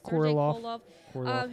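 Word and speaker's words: Korolev: Joseph Stalin actually got Sergei Krolov. How do you pronounce his name? – Korolov.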